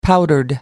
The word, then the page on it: powdered: American English